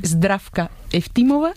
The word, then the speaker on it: Zdravka Eftimova: Zdravka Eftimova y Vera Mutavcheva.